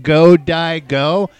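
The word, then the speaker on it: Godiego: Go Daigo. And then when I looked it up, it spelled that way anyway. It spelled exactly how you would go, die, go. – Go. But it's Go Daigo.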